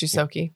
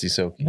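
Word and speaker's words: Ysoki: Oh, it's pronounced Yusoki. – Actually, it is pronounced Yusoki.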